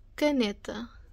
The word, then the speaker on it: caneta: It's caneta. Yeah, it's a longer word. Let's go syllable by syllable. Ca, ne, ta.